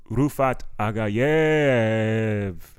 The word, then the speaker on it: Rufat Agayev: I said, you know, my man, give it up for Rufat Agayev.